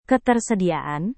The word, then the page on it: ketersediaan: indonesian